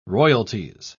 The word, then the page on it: royalties: British English